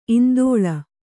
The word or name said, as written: ಇಂದೋಳ